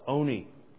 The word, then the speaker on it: Olney: And if you pronounce it Olney, well, you're definitely not from Olney, because they don't pronounce the L. It's Oney.